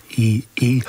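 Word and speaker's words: Y'Y: That's taken from an album called To Us It's YY, but it's pronounced EE, and Uiara are the song of water is the one that you heard, UIARA.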